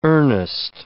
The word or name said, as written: earnest